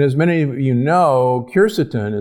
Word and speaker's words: quercetin: The pith contains large amounts of polyphenols and also phytonutrients, including a compound that you've heard about, quercetin, sometimes pronounced quercetin, that is present in the pith of citrus.